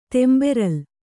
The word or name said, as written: ತೆಂಬೆರಲ್